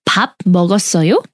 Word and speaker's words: 밥 먹었어요: We ask, 밥 먹었어요? – Gotta break it down.